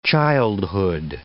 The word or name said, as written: childhood